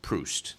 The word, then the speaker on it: Proust: Mr. Millen just weighed in to point out that technically it is more correctly Marcel Proust. – That's the proper pronunciation.